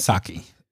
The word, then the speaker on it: Psaki: Psaki. The P is silent.